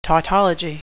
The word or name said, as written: tautology